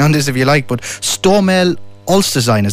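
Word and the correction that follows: Olsztyn: is that right? – Stomel Austin. – Austin, close enough.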